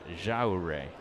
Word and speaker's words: Yajure: Miguel Jaure. He's young, 25 years old, from Venezuela. Last name spelled Y-A-J-U-R-E. So there's a throw to first, and back in is Marlowe. And that's pronounced Jaure.